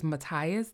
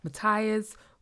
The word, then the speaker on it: Matthias: And then I had questions about that, because we had I think it was Matthias, that's how you say his name, Matthias, who claimed that he killed her father.